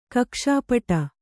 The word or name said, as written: ಕಕ್ಷಪುಟ